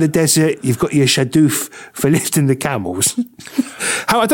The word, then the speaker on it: shadoof: Well, I'll spell it for you. S-H-A-D-O-O-F. You see, this is like a footballer that's come over. And I'm going, oh, it's shadoof. Shadoof, he's left back now for Arsenal. And in about 3 weeks' time, we go, no, it's shadoof. That's how you pronounce it.